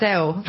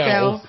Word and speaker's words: Adele: He came and asked me how I say my name, and I was like, Adele. – How was mine? – She was like, Adele.